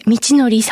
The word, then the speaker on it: 道徳: あの、小口さん、お名前が、こう、道徳と書いて、道のりさんと読まれるんですね。 – はい、そうなんですよ。